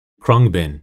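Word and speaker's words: Khruangbin: Krungbin. I feel like I just learned how to pronounce. I've been listening to you guys since 2015, and I think I just now, I was worried I was pronouncing it wrong this entire time right now, but I think I finally got it.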